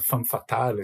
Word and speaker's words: femme fatale: A Sean Young também, ela parece uma daquelas female fatales do... Female fatale, não, eu falei errado, perdão, é uma femme fatale, assim, dos anos 30, dos anos 40.